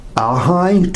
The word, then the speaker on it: ahi: You've got from anything from ahi tuna, is that how they say it? Is it ahi? – Ahi tuna? – Yes.